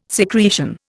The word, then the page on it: secretion: Indian